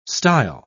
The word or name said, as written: style